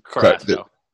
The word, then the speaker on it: Carrasco: Carrasco. Carrasco is three.